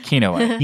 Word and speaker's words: Kinoite: Kinoite. – This is also a pronunciation show, if you all didn't know. Kinoite. – You're adding an extra N.